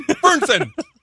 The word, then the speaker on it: Fernson: Fernson.